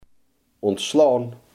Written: ontslaan

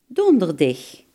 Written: donderdag

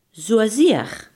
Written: zozeer

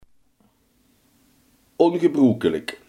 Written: ongebruikelijk

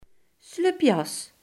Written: jacquet

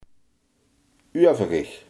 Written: overig

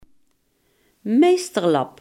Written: dwingeland

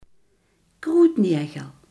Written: kruidnagel

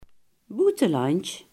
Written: buitenland

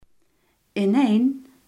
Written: ineen

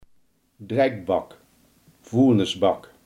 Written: vuilnisbak

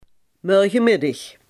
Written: morgenmiddag

